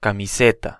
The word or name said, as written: camiseta